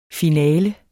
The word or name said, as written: finale